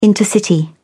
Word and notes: intercity: British English

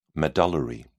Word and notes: medullary: British English

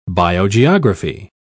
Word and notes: biogeography: British English

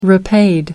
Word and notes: repaid: American English

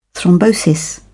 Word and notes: thrombosis: British English

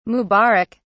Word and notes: mubarak: British English

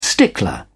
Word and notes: stickler: British English

